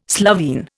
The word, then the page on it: Slovene: Indian